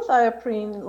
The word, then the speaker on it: azathioprine: Another assault in 1987 was the drug AZT, which is a nucleoside analog, but you also had experience with a drug called, and I'm going to mess up the pronunciation, azathioprine? – Azathioprine.